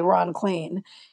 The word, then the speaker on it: Klain: And there's no one in Washington who has any confusion about how you say Ron Klain. It looks like rain and Spain and plain, yeah, and Maine and vain, and it rhymes with all of those things.